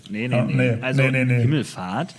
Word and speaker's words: Himmelfahrt: Ja, ich habe es ein bisschen Englisch ausgesprochen und habe ich gemerkt, oh. – Fahrt ist ja was anderes. Fahrt in Englisch, das, nee. Himmelfahrt, Deutsch.